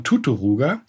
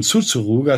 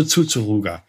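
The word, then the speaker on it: Țuțuruga: Ich hatte also erkannt äh, durch das Suchen im Internet, dass da ein äh, Haken unterm T war und dass man es mit C aussprechen muss. Wenn du da suchst nach der Erklärung von Tuturuga, oder von Tsutsuruga ist natürlich ein riesengroßer Unterschied, ne? Ja, klar. Und dann dachte ich, okay, also Tsutsuruga.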